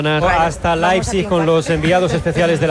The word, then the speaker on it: Leipzig: Leipzig es lo que nos dijeron que teníamos que decir.